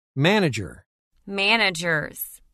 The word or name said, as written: Manager – Managers